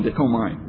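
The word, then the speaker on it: dekomai (δέχομαι): Anyway, it would be, yeah, dekome. No, dekomai, because the AI is like an aisle.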